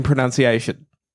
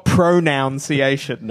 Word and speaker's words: pronunciation: I was an English teacher, and I think I was 29 when I found out that there's only two O's, I should say, in pronunciation. – Yes. – There's no O in the middle. – That's right. – It's not pronunciation.